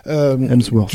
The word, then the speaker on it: Hemsworth: Mais sur l'incapacité à choisir le ton, effectivement, avec le personnage de Mengele joué par Hemsworth, excusez-moi pour la prononciation, Hemsworth.